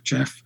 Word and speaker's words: Gef: Um, he couldn't spell, uh, so it was phonetically G E F. It's uh, Jeff.